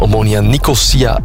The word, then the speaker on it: Omonia Nicosia: STVV verloor dan weer met 4-2 van Omonia Nicosia uit Cyprus en zakt zo naar de groepsfase van de Conference League. Dat is de juiste uitspraak.